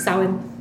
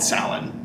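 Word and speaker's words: Samhain: I know it's called Salin. – You did say it properly earlier today. And I did. Yeah, because I know what it is. And it's spelled like Sam Hain.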